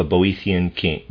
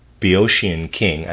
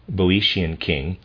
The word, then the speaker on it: Boeotian: Phrixus and his sister Hela, children of a Boethian king, Boeotian king, I don't know how to pronounce that, B-O-E-O-T-I-A-N, Boeotian king, suffered much at the hands of their stepmother.